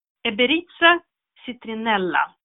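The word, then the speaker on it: Emberiza citrinella: Eberitza citrinella. Mm. Beritza citrinella. På båda eh, blir betoningen hamnar på eh, andra stavelsen från slutet. Det blir alltså näst sista.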